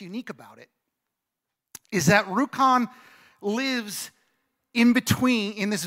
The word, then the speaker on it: Rjukan: There's this little town in Norway called Rjukan. Now, I'm butchering the name because I can't roll my R's the way they would do that in Norway. But Rjukan.